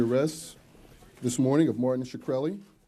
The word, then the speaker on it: Shkreli: Is it pronounced Shkreli? – Yes, sir.